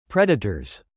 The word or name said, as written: predators